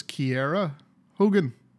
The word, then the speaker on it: Kiera: So her, she pronounces this Kiera Hogan. – Yeah, Kiera Hogan. – It's not Kiera. – It's not Kiera, it's Kiera.